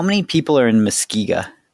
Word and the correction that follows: Muskegon: Muskegon. – Did I say it right? – Muskegon.